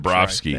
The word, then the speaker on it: Bobrovsky: Bobrovsky.